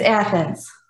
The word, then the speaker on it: Athens: Athens campus, Athens or Athens? I'm not sure how to pronounce that. – It's Athens. – Athens, okay, great.